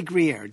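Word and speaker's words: Gruyère: Just say Gruyere. Don't even look at how it's spelled. Gruyere. – Okay. – Yeah, it is Gruyere. I'm pronouncing it the French way.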